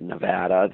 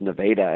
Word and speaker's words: Nevada: spelled like Nevada, the state, but in Iowa, the town is pronounced Nevada, and they had two wind turbines right there at their school.